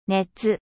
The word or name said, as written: chaleur